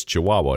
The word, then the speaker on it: Chihuahua: No, Chihuahua. And I was like, I know you're just I'm glad that you were excellent at, you know, sounding this stuff out phonetically, but it's Chihuahua.